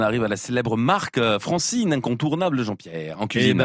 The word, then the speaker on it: Francine: France, première syllabe, franc, et farine, dernière syllabe, in, donc Francine. – Contracté, on arrive à Francine.